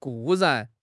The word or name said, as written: 固然